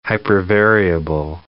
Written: hypervariable

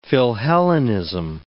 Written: philhellenism

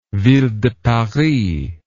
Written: ville de paris

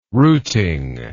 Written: routing

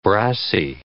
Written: brassy

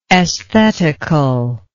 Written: esthetically

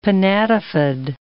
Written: pinnatifid

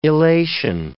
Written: elation

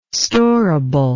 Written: storable

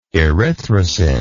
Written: Erythrocin

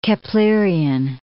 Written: Keplerian

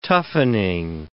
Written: toughening

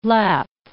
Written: lap

